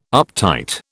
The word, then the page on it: uptight: British English